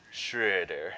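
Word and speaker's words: Schröder: Get that umlaut in your pronunciation. – Schroeder.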